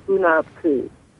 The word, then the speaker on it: Hunabku: And it's spelled H-U-N-A-B, and then Ku, capital K-U. So, Hunabku.